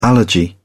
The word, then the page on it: allergy: British English